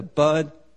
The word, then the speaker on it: bud: Is that how you say it? Nip it in the bud. I always thought it was butt. But I just didn't say want to say butt, so I say bud. But it is the right way to say it.